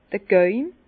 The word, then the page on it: d'r Gäume: Bas-Rhin; Herrlisheim